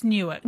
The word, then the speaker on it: Newark: It's Newark. – Newark? – Yeah.